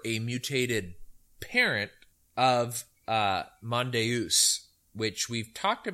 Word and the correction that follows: Mondeuse: about, I think, once or twice before, not in any depth, but, and I could be also pronouncing it wrong. It's Mondeuse, which is a black French grape varietal.